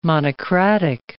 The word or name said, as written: monocratic